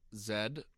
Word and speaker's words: zed: Zed. I don't know why we changed the pronunciation of a letter. – It's Zed. – It's Z, too. – No, it's not Z. We're Canadian.